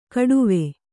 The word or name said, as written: ಕಡುವೆ